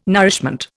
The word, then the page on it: nourishment: Indian